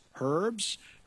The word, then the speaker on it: herb: In addition to the earth and the seas, it says in verse 11 and 12 that grass, herbs, fruit, and vegetation were made. Herbs, grass, herb, the H is silent, and fruit and vegetation were created.